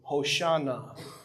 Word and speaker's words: Hoshana: In fact, Jesus' name in Hebrew is Yehoshua, which sounds an awful lot like Hosanna, or as Jews would probably pronounce it in Hebrew, Hoshana.